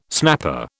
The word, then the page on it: snapper: British English